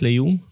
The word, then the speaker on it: Leung: Man Lok Lung. Wat een kennismaking met Lung. – Ja, of Leung. Werd, in Nederland ook gezet, Leung. Dat je het zo moet uitspreken.